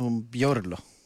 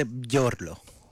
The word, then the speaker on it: Μπιόρλο: Στην ειδησιογραφία όντως δεν έκλεισε αυτός ο Μπιόρκ. Που δεν είναι Μπιόρκ. – Ο Μπιόρλο. – Ο Μπιόρλο. Να βάγισε τον Μπιόρλο στην ΑΕΛ. – Αν μάλλον προφέρεται Μπιόρλο.